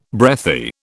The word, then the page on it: breathy: British English